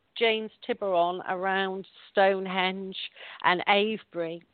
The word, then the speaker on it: Avebury: And that's A-V-E-B-U-R-Y, Avebury. But I love the way Americans say it, Avebury.